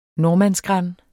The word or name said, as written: nordmannsgran